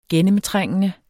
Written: gennemtrængende

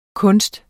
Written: kunst

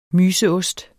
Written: MYSEOST

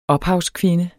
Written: ophavskvinde

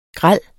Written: grel

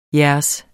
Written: jeres

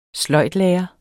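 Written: sløjdlærer

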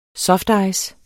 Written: softice

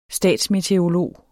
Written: statsmeteorolog

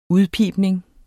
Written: udpibning

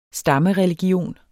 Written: stammereligion

